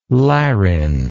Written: larine

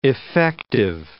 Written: effective

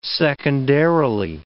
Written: secondarily

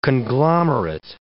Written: conglomerate

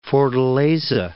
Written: Fortaleza

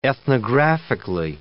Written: ethnographically

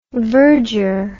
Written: virger

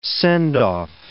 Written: send-off

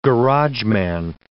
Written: garageman

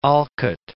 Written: Alcott

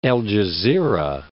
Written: El Gezira

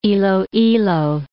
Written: Iloilo

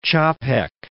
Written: Capek

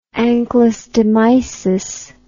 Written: anchylostomiasis

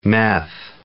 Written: meth

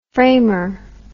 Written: framer

